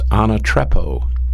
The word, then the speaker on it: anatrepo: It is pronounced anatrepo, A-N-A-T-R-E-P-O, anatrepo.